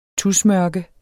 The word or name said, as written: tusmørke